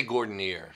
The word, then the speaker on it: Gordinier: Sometimes I get the French pronunciation, but that was lost centuries ago. – How do you say it? – We say Gordinier. – Gordinier. – It's been Jersified at this.